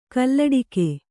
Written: ಕೊಲ್ಲಟಿಗ

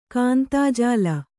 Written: ಕಾಂತಾಜಾಲ

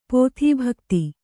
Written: ಪತಿಭಕ್ತೆ

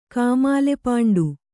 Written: ಕಾಮಾಲೆಪಾಂಡು

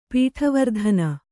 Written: ಪೀಠವರ್ಧನ

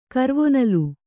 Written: ಕರ್ವೊನಲು

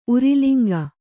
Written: ಉರಿಲಿಂಗ

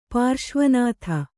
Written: ಪಾರ್ಶ್ವನಾಥ

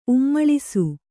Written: ಉಮ್ಮಳಿಸು

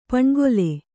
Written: ಪಣ್ಗೊಲೆ

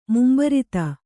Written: ಮುಂಬರಿತ

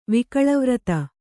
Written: ವಕೀಲಿವೃತ್ತಿ

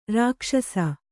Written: ರಕ್ಷಸ್ಸು

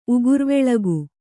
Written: ಉಗುರ್ವೆಳಗು

